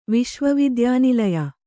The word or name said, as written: ವಿಶ್ವವಿದ್ಯಾನಿಲಯ